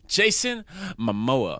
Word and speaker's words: Jason Momoa: Anyways, we're taking you guys to the underworld world of the Seven Seas, Aquaman, starring Jason Momoa. Did I say it right? – Yep.